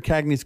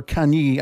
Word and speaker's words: Kanye: And through Mr. Cagney's collections, or Kanye, I would imagine is the pronunciation,